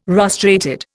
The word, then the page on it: rostrated: Indian